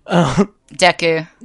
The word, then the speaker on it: Deku: Deku? – Deku.